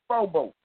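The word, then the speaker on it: phobos (φόβος): The first is deilia, and the second is, uh, I want to make sure I pronounce this right, is phobos.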